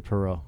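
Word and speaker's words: Poirot: It's Inspector Perrault. – Yeah, there we go. He said it with a French accent. – Perrault, yeah. – Yeah. Perrault. – It's Pyro or Perrault.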